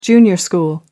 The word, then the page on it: junior school: American English